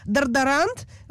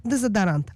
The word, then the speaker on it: дезодорант: Собственно, почему некоторые израильтяне, ну не все, конечно, говорят «корасон» вместо «круасон», «дардарант» вместо «дезодорант», а слово «акзоз» и «пункчер» стали давно частью разговорного иврита.